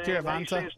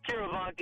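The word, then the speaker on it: Kiviranta: Carivana, or however you say his name. – Carivanta. Carivanta. – Carivanta, yeah.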